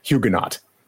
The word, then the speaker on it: Huguenot: Did you look into, is that actually how we pronounce it? Because that's got a funny spelling. – Yeah, it does have a funny spelling, which is why I Googled the pronunciation before recording the episode, like I always do when I am presented with a word that I'm not sure I can pronounce. And the only time I've got it wrong so far is with Coos County up in New Hampshire. So yeah, Huguenot.